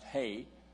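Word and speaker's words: hey (ה): And the letter there is he or he, is pronounced he, is spelled H-E. And in English, and it's pronounced hey.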